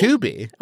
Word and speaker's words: Tubi: Is it Tubby now? – What? There's no way that's how that's pronounced. I thought Tubi? It was Tubi.